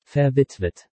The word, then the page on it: verwitwet: German